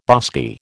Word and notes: bosky: American English

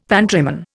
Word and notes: pantryman: Indian

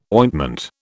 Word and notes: ointment: British English

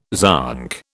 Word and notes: zonk: British English

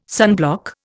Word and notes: sunblock: Indian

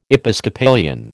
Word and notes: Episcopalian: American English

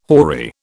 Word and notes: hoary: British English

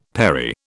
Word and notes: perry: British English

